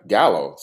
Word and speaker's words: Giallo: Giallo, see, there you go, pretty good.